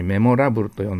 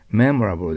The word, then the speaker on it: memorable: これを日本語のようにメモラブルと呼んだらダメです。 memorable でラはほとんど曖昧な発音だから、おーっとしか聞こえませんよ。